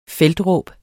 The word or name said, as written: feltråb